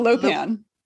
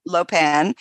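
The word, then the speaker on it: Luopan: Oh, yeah, how do you pronounce that? – The Lopan. – Lopan. – Yes.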